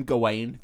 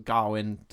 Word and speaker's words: Gawain: I'm going to call him Gawain throughout this, because Garwin doesn't make any sense to me.